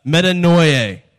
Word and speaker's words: metanoia: Metanoia. Right? Metanoia. Think of like the word annoying. And then put metanoia. That's how you say it.